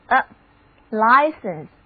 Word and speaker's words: license: 字母 I 读它的本音，而字母 E 则读作短的 a license， a driver's license。